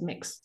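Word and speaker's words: Mx: is mix, which is spelled M-X, and it's pronounced as mix.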